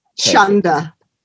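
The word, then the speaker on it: chunder: Chunda. – Chunda or Shunda? – Chunda, as in... – C-H-U-N-D-E-A. – Chunda. – Chunda. – It rhymes with thunder.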